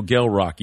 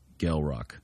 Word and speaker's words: Gel Roc: There is no K, and it is pronounced with a hard G like graffiti. Gel Rock, not Gel Rock.